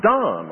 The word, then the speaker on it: Donne: I called him John Donne all this time until I heard an Englishman who carefully told me that you pronounce D-O-N-N-E, Donne.